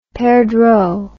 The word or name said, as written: perdreau